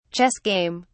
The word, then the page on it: chess game: British English